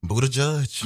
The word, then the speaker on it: Buttigieg: Now that I know how to pronounce his name, I love it. Buttigieg. – Mm-hmm. – You better boot that judge.